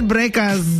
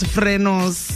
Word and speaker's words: frenos: The right way to say it, apparently, is frenos.